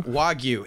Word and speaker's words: Wagyu: Am I even saying it right? Is it Wagyu? – Wagyu. It comes from Japan.